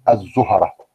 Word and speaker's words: الزهرة: نطق كوكب آه الزهرة احنا بنخطأ عندما نقوم بتسكين الهاء لكن الموجود في المعاجم الزهرة بفتح الهاء أيضا